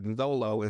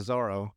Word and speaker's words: Zoro: uh Roronoa's Zolo because it's in the manga Zolo instead of Zoro. – Mm-hmm. But apparently in Japanese it's interchangeable, so Zoro and Zolo are the same thing because the L's and R's are changeable.